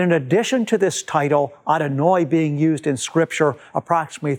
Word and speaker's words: Adonai: And in the Siddur, God's name in Hebrew is pronounced Adonai. Now, Adonai is used approximately 300 times in the Hebrew Bible. It simply means Lord or Master. The reason that Jewish people use this title for God, Adonai,